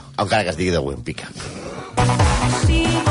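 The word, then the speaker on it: Łempicka: també coneguda com Tamara de Güempica, perquè, a veure, la L de l'Empica sí. es pronuncia Güempica. – Ué. Té aquesta Ué. ratlleta com el Boitila, com el papa.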